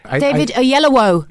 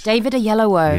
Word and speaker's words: Oyelowo: How do you say his name? Yololo. – David Oyelowo. – Ah. – Oh, thank Christ. – Yes. – David Oyelowo.